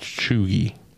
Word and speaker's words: cheugy: Okay, so this one is Chugy, spelled C-H-E-U-G-Y, pronounced Chugy. – C-H-E-U-G-Y.